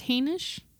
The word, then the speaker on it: Hainish: And I think it's important also to note that is it pronounced Hainish? – I think so. – Yeah, so it's H-A-I-N-I-S-H.